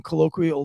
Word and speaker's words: colloquial: That was how the president decided to open up his speech and address the nation about this, with some colloquial jokes. Did I say that right? Colloquial? – Yes. Jokes. You said it right for Eric.